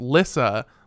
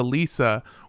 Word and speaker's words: Lissa: but they said that it's pronounced Lissa, like Alyssa, and that anyone who pronounces it anywhere close to Lisa will be met with death.